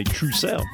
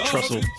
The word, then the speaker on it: trousseau: This would be, well, depending on how you say it, trousseau or trussel. We never knew back in the day how you said this. – How do you say it? – I think you say trousseau, and then you say my oh my. – My oh my. – Trousseau. – Love injection. – Not trussel.